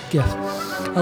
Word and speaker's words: Jif: Environmental science, it's pronounced Gif. – Gif.